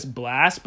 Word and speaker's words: Blxst: His name is, I believe, don't quote me on this, Blast, but it's spelled B-L-X-S-T.